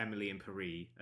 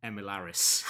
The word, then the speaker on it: Emily in Paris: Of everyone premonitioning that Emily in Paris, or Emily in Paris, as the Netflix Twitter account very kindly informed as it was meant to be pronounced, is coming back. Although that also, my favourite thing about that tweet was someone quote tweeting it and saying, like, who pronounces it Emilaris?